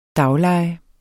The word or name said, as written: daglejer